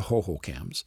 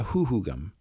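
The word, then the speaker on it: Hohokam: In this episode, you'll hear it pronounced that way by several people, including myself, but it's incorrect. The Salt River Pima Maricopa Indian community is currently trying to reclaim the correct pronunciation of a word that means the departed ones, the Hoohoogum.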